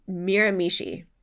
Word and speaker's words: Miramichi: But I did look up, it's Miramichi. Miramichi. There's two different pronunciations I've heard so far now.